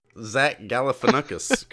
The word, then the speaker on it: Galifianakis: Zach Galifianakis, come on the podcast. – Right. That's how you pronounce it. Yep, you nailed it.